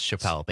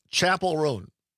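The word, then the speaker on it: Chappell Roan: I would have also guessed Chappelle based on how it's spelled.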